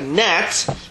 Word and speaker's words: gnat: So he went back on land, and it says that there was a yitush, which is a gnat, silent G, went into his nose and caused some type of commotion upside in his brain.